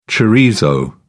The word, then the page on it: chorizo: British English